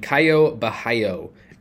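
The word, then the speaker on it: Caio Borralho: Moving on to tournaments, I'm going to mention Caio Bahio at 9K. Yes, that is how you pronounce his name.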